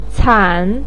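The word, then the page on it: can3: tone 3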